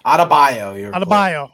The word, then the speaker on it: Adebayo: Bam Adebayo. I believe that's how you pronounce his name. I always butcher it. – Adebayo.